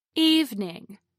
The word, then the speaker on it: evening: Evening is pronounced with two syllables instead of three. Evening, not evening.